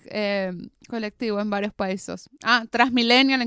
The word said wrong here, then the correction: Transmilenio: en Colombia, por ejemplo. – Transmilenial. Sí. Que es un millennial trans. Transmilenio. Transmilenio es el coso.